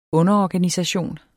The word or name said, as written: underorganisation